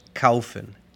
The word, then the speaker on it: kaufen: Es AU, AU. Por ejemplo, en comprar, kaufen. K-A-U-F-E-N.